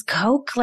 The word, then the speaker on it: Koch: Because but that's not how you say my name. – K-O-C-H. And all the K-O-C-Hs I know are cocks. – No, it is Coke, like the soda.